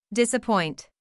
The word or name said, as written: disappoint